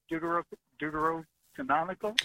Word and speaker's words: Deuterocanonical: My question is when, because I know the Deuterocanonical.